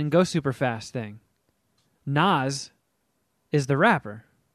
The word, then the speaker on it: Nas: Is it pronounced Nas or Nas? – Is it Pam or Pam? – I mean, It's Nas. – Yeah, Nas is like the Fast and the Furious.